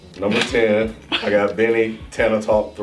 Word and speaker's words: Tana Talk: At number 6, I have Benny the Butcher, Tana Talk. I said that right? – Tana Talk, yeah.